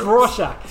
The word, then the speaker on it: Rorschach: But it wasn't until I saw the film that it's like, oh, that's how you pronounce Rorschach. You know, it's Rorschach.